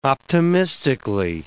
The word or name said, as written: optimistically